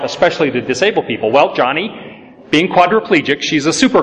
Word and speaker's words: Joni: People think her name is Joni, J-O-N-I. It's actually pronounced Johnny.